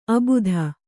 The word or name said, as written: ಅಭಿಧಾ